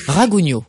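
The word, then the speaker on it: Ragougneau: Alex Rougagno, Évangile pour un gueux. – Ragugno. – Ragugno, pardon. Oui.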